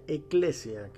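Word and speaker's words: ecclesia: La palabra santo significa separado la misma palabra iglesia que pronunciándose en su idioma eh, original se pronuncia eclesia, que haciendo referencia a algo que es tomado de en medio de o separado de.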